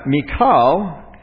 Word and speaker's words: Michal: And now, Michal, which is where we get Michelle. Verse 20 is really how we had pronounced Michelle, but it's Michal.